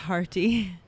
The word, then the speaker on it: Mharti: It was called Doc and Marty, and Marty is spelled M-H-A-R-T-I. – M-H? – Marty.